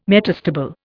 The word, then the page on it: metastable: Indian